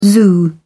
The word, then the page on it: zoo: British English